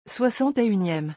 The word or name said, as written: 61eme